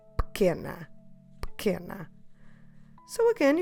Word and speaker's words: pequena: P-E-Q-U-E-N-A. Pequena. So you've noticed that the U is silent, but also that first E is silent. We're stressing que, the second to last syllable in the word.